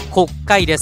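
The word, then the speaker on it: 告解: 見出しにある国会というのは告白の告に解決の会と書いて国会です。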